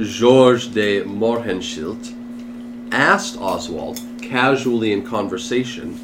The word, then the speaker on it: Mohrenschildt: You know uh, Georges de Mor- Mohrenschildt? Mohrenschildt, very Is that how you say it right? – Yes. Wow, I finally got it correct.